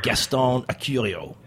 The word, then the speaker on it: Gaston Acurio: It's a profile of one of the world's most, one of the most dynamic chefs on the planet, Gaston Acurio. How was that pronunciation, Seth? – I think you got it pretty well.